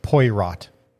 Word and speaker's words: Poirot: I believe that's pronounced Poirot. – Poirot. – It's the French, man.